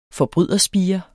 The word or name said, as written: FORBRYDERSPIRENS